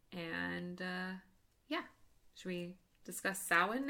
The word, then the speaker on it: Samhain: Yep, Samhain.